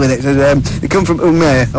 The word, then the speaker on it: Umeå: So what pronunciation derivation would that result in, like? – Umeå.